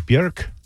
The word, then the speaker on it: Björk: Unless you speak Icelandic, correct pronunciation is Björk. I'm not kidding, but you've been saying Björk this whole time.